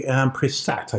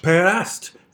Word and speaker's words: Perast: We have been to Kotor, and we had lunch at a lovely little town whose name escapes me, Prisat, I think. – Perast.